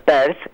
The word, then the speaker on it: Peirce: a partir de Charles Sanders Peirce, se debe pronunciar así, no es que me equivoco, P-E-I-R-C-E,